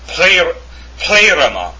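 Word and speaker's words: pleroma (πλήρωμα): And it's pleroma. The accent is on the eta.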